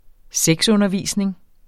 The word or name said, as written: sexundervisning